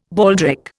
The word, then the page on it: baldric: Indian